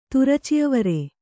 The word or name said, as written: ತುರಚಿಅವರೆ